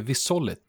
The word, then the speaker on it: Visolit: Nej, men eh, det här är ju, det är många som säger fel, men det heter Visolit.